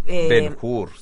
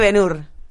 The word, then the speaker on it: Ben-Hur: Me gustó porque le dice Ben-Hur. – Ben-Hur. – Ben-Hur, y no hay caso, le digo Ben-Hur. Ben-Hur. – Ben-Hur. – Ben-Hur. – Es que es verdad que no se debería pronunciar la H.